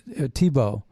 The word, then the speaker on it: Thibault: So it sounds like Tim Tebow, but it's Timothy Tebalt, and it's pronounced this way, Tebow, but it's spelled T-H-I-B-A-U-L-T, like a vault, but with a B.